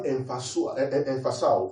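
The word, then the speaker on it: emphasal (ἐμφυσάω): Now, the word breath on them is the word emphasal in the Greek.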